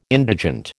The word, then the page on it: indigent: American English